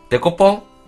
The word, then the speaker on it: デコポン: デコポンフワちゃんフワちゃんと同じイントネーションキャサリンと同じじゃないですかね。